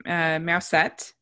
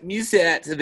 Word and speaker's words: Mewsette: Yeah, so Judy plays a cat named Mousette, and Mousette. They spell it Mewsette. So, again, you know, cat French cat puns.